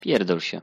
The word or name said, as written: pierdol sie